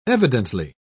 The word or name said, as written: evidently